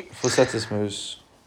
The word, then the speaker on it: Fortis Muse: How do you say her username? Fortis Muse or something like that. She's the one with like the HR person with the bunny. – Fortis Muse. – Yeah, exactly. We met her at the tournament as well.